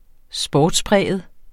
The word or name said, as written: sportspræget